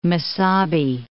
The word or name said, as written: Mesabi Range